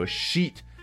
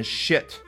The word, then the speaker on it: sheet: 比如说啊，经常在企业里面有人把那个 Excel 表格 sheet 念成 shit，老外一旦听到这个就是那种。